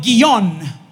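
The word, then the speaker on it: Gihón: Porque se menciona en la Biblia un río llamado Guión, g i H-O-N, guión, con acento en la última O.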